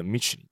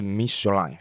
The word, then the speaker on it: Michelin: 因为啊，米其林轮胎是在一九八诶一八八九年的时候呢，有一对米其林兄弟他在法国创立的轮胎品牌，所以呢，这个米其林的名字其实它不是英文发音的 Michelin，它其实是 Michelin 法文发音。